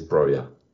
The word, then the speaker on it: Broja: Like, he's not Spanish, he is Albanian, so yeah, Broja it is. – Broja, okay, I have to remember that.